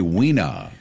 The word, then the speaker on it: wiener: Why do they say wiener? – Wiener, Oscar Mayer wiener. – They don't pronounce the R.